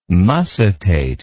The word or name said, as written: mussitate